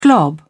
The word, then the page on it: glob: British English